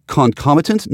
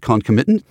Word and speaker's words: concomitant: Or the other one I'm pronouncing wrong, it's concomitant, not concomitant.